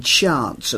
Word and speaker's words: chants: They use the long A in chants, and their rhymes are fun.